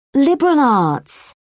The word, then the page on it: liberal arts: British English